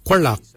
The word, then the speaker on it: gwelyag: For your hips, we say Kualak. Kualak is your hips. Kualak is spelled G-W-E-L-Y-A-G. Kualak. Kualak is your hips. Your backside is your quan. Your quan is spelled G-W-A-N.